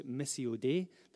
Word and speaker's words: Missio Dei: Glenn is the vice principal of a Bible college, Missio Dei. That's the proper way to pronounce it, I believe.